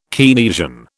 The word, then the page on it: Keynesian: British English